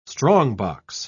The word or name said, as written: strongbox